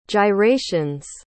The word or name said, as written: gyrations